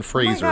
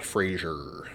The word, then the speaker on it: Fraser: And just for the record, it's pronounced Fraser, not Frasier.